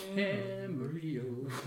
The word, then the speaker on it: Camarillo: She moved down, and I think she played Camarillo. – No. – Camarillo. – That should be our intro. – That's how it's pronounced. That's how it's pronounced, yeah.